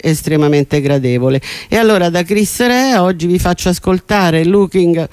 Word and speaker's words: Chris Rea: Cominciamo con un artista irlandese, ma in realtà lui è figlio di un italiano e di una irlandese, si chiama Chris Rea, io lo pronuncio all'italiana, anche se ovviamente internazionalmente è conosciuto come Chris Rea.